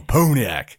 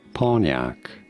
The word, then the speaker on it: Pontiac: This is usually said as Pontiac, Pontiac automobile.